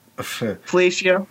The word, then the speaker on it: Fellatio: Fellatio. – It's a medical term. – Rhymes with Horatio, which is, by the way, what the H in Jesus H Christ stands for.